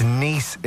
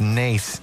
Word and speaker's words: Nice: It's Nice in the south of France, not Nace in County Kildare. It's a pronunciation thing.